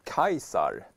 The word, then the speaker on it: Caesar: Eh, Emil Åkert påpekar att Caesar uttalas väl typ 'Kaisar' på latin.